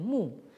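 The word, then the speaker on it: mu (木): qui se prononce en chinois moderne mu.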